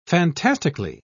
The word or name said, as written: fantastically